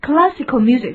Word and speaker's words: classical: 这个例子发音它跟课堂不一样，课堂是 class, class, 发长音的 R, class, 那么这里面 classical music, 发那个梅花形的 L 音，读作 classical music, 叫古典音乐的意思。